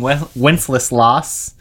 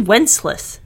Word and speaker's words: Wenceslas: It's definitely Wenceslas. – It's Wenceslas. – Look how you spell it. – Wenceslas. What's that? L-A-S at the end, dude. – Wenceslas.